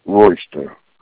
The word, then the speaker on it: Royster: Willie Royster. – Willie, how do you spell that last name? – R-O-Y-S-T-E-R. – S-T-E-R, great.